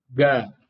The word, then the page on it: g: Sanskrit